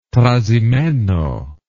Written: trasimeno

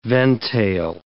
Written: ventail

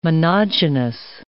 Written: monogynous

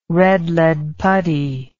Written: red lead putty

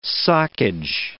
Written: socage

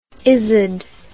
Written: izod

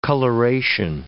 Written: coloration

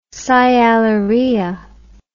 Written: sialorrhea

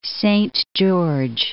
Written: Saint George

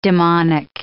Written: demonic

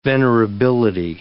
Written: venerability

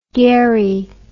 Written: garry

